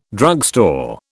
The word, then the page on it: drugstore: British English